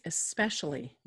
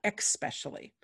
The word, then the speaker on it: especially: The word is especially, not expecially.